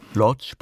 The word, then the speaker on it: Łódź: Lodz, polnisch ausgesprochen Łódź, war ihre Heimat, die sie im Grunde nie verlassen hat.